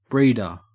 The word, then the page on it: breeder: Australian English